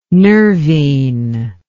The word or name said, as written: nervine